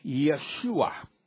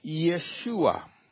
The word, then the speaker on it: Yeshua: Now, the Hebrew pronunciation of Joshua is Yeshua. Yeshua.